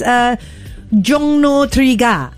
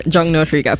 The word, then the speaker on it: Jongno 3 Ga: Before we carry on, can you correct the pronunciation? It didn't sound quite right. – What did you say? – Okay. Jongno 3 Ga. – Wait, hold on. Let me find it again. Here it is. Jongno 3 Ga. – Yeah, Jongno 3 Ga. – Well done.